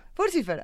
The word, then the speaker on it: Furcifer: Furcifer, ajá. Sí, es que se pronuncia como, Lucifer. Él llegó y nos dijo, es como Lucifer. Es como Lucifer, Furcifer.